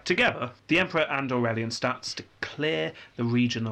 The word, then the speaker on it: Aurelian: Is it Aurelian? – It is Aurelian. – Yes, Aurelian. – Yeah. Or Aurelian. I don't know, I've always said Aurelian.